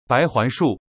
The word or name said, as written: baihuanshu